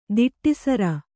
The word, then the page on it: ದಿಟ್ಟಿಸರ: Kannada